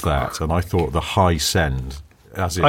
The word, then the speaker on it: Hiscend: Hisend, not as in like It's H-I... Oh, hang on. I've just gone off the... The Dynamo, it's spelt H-I-S-C-E-N-D. As in ascending? – High-send.